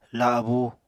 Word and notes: labu: Apatani

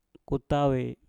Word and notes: kutawe: Mpur

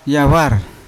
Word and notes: yavar: Yawa

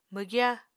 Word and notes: mwgya: Apatani

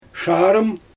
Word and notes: ʃaarɯm: Xyzyl

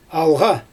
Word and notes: alGa: Xyzyl

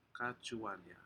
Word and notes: kajauanya: Aneityum

